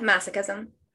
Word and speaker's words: masochism: And then sadism and masochism. Did I say that right? – Yeah, masochism.